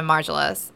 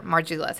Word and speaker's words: Margiela: But none of those versions nor any others attract the attention of Margiela's or Margiela's.